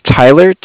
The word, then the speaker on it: Tyler: Okay, my name is Tyler, T-Y-L-E-R, and I'm calling with Cardiff. It's spelled C-A-R-D-I-F-F, as in Frank, Frank.